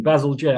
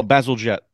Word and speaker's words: Bazalgette: like jet engine, Bazalgette. – Oh, Bazalgette.